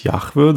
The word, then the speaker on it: Yahweh: Im Hebräischen würde ich sagen, heißt es Yahweh.